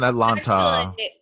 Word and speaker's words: Atlanta: Yeah, what's going on in Atlanta? – The second T is silent, so it's Atlanta.